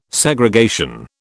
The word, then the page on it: Segregation: British English